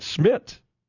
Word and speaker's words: Szmytz: This kicker, if I'm not mistaken, and again, we're still in the Google free zone, has two Z's in his name, and I think both are silent. Andre Schmidt, spelled S-Z-M-Y-T-Z. – But maybe the Z at the end? – You just want to pronounce it Schmidt.